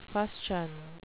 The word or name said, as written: fustian